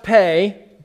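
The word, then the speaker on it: Pe: I'm excited because someone pointed out to me that this section shares my name, P.E. Actually, it's pronounced Pay.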